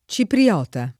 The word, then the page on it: cipriota: lingua italiana